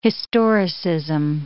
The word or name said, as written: historicism